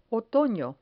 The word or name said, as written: otono